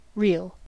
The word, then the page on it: reel: American English